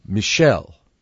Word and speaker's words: Michel: And has he ever heard someone named Michael be called Michel, which is the French pronunciation?